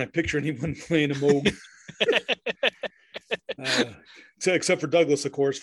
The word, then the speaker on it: Moog: Dr. Robert Moog, it's spelled Moog, M-O-O-G, but I do believe it is pronounced Moog, demonstrates the Moog synthesizer prototype.